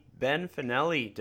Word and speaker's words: Ben Fanelli: I'm joined by the one and only Ben Fanelli.